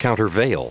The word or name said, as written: countervail